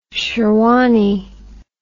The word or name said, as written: sherwani